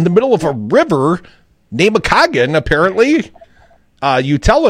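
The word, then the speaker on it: Namekagon: We used to go up there every winter when I was a kid, and yeah, Nemecogon is how you say that.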